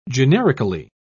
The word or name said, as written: generically